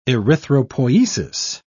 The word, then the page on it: erythropoiesis: British English